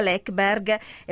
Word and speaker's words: Läckberg: Sottolineo che si pronuncia Läckberg, perché noi in Italia, devo dire, anche io fino a qualche tempo fa la chiamavo Läckberg, e invece insomma lei insiste nel dire guardate che il mio cognome è Läckberg, e quindi ok.